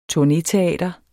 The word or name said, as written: TURNETEATER